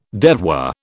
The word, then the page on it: devoir: British English